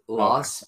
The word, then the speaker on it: LSST: It's pronounced Loss, but it's spelled L-S-S-T.